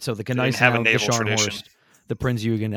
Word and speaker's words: Gneisenau: Right now, Gneisenau. – Yes. See, the Gneisenau.